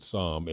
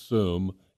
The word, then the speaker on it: Soom: you might pronounce it Somm. It's not. It's Soom, S-O-O-M.